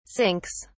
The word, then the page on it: sinks: British English